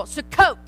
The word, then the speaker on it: Sukkot: And now put the emphasis on the second syllable, Sukkot.